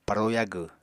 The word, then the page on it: paro yagw: Apatani